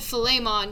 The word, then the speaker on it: Philemon: So we were saying it wrong, and we looked it up, and it said Philemon, because it was kind of like filet mignon, so I won't forget it now.